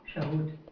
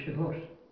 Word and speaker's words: Shavuot: What is it? – Shavuot. – Shavuot. – It's actually Shavuot. Because sometimes they say it, yes, it's a T or the T, depending on what you do.